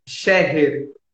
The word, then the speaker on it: Scherrer: Mas a Ellen, Ellen Escher, é assim que se pronuncia? – Scherrer. – Cher, ok.